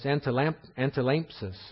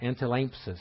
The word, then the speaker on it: antilampsis: The gift of helps or service, as was mentioned before, that's antilampsis is how it's pronounced, antilampsis. It's number 484. I apologize for my pronunciation. I'm not a Greek scholar. Antilampsis is how that's pronounced.